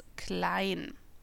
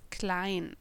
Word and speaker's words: Klein: Guten Tag, mein Name ist Tobias Klein.